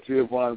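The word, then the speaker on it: Giovani: Giovanni, is that how you pronounce it? Giovanni Bernard, is that his name? – Yeah, Giovanni Bernard.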